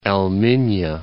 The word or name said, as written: El Minya